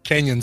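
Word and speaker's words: Cañon: Canyon City, yeah. – Oh, it says Cannon with a with the umlaut over it. – A tilde. – Yeah. So it's pronounced canyon.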